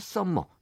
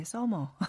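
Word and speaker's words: summer: 이핫 썸머 이런 노래가 흥얼거려진다라는 또 원문도 있었습니다. – 옛날에 우리 학교 다닐 때 썸머라 그러면 혼났는데 서머. 아, 네, 네. M은 네. 하나만 발음해 주시기 바랍니다.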